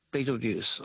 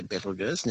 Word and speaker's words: Betelgeuse: But the companion star is orbiting Betelgeuse, or I should say Betelgeuse, now we're pronouncing it correctly.